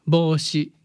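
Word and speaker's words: Boshi (帽子): How do you say hat in Japanese? – Boshi. – Is it B-O-U? Boshi? – Sounds like that. – I see. – Mm. Boshi. – I think there's a long sound.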